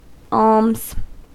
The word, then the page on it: alms: American English